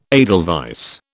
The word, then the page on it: edelweiss: British English